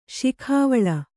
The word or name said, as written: ಶಿಖಾವಳ